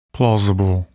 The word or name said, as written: plausible